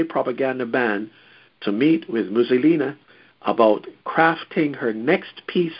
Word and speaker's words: Mizulina: And that's, of course, Mussolina is spelled M-I-Z-U-L-I-N-A. So let me read that sentence again, because I don't want you to think it's Mussolini of the past.